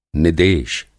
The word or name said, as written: निदेश